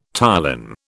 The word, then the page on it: ptyalin: British English